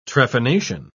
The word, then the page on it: trephination: British English